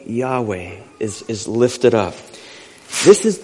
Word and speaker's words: Yahweh: The four Hebrew consonants, often pronounced Yahweh.